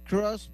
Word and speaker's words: Cruz: Cross, Cross.